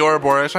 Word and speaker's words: Ouroboros: Uh, there are off, often um, the snake can invite to the mind an image of the uh, I don't know if I'm actually pronouncing it right, but Ouroboros. – Oh yeah, the Ouroboros. I'm pretty sure that's how you say it.